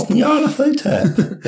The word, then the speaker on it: Nyarlathotep: So we got back together in 2010 to play Call of Cthulhu, Masks of Nyarlathotep. – It's Nyarlathotep. Why are you making it so hard to pronounce? – Monthly for 3 years.